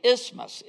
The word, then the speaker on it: isthmus: Corinth was located on a narrow strip of land known as an isthmus. It's spelled like isthmus, but we just say isthmus.